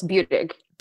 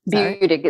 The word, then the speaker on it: Buttig: Buttig. – Buttig. – Buttig. It's a long U and then dig. Buttig.